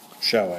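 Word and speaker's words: shew: Meanwhile, the moon looked down upon this shoe. – Show, actually. – Show. – No, it's spelled shoe, but it's pronounced show. – Meanwhile, the moon. – It's like sew, like when you sew.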